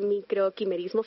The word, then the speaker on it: microquimerismo: ¿Sabe que con lo que decía usted del microquimerismo fetal, si ¿sí lo pronuncio bien? – Sí, señora.